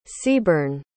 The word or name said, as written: seaborn